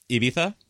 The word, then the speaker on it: Ibiza: I really liked your pronunciation on the Z on Ibiza. – Ibiza? – Yeah, no, very good.